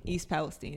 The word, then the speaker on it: East Palestine: East Palestine is how it's pronounced.